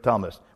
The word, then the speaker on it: Thomas: Derek Thomas. We don't pronounce the H for Thomas, right? Derek Thomas.